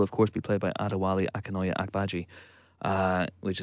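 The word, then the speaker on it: Adewale Akinnuoye-Agbaje: Adewale Akinoye Agbaje.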